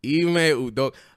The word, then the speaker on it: Udoka: Udomi Tomisco. – Oh, my God. That's not how you say his name. It's Ime fucking Udoka or some shit like that. – Yes. – Ime Udoka.